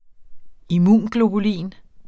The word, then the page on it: immunglobulin: iˈmuˀnglobuˌliˀn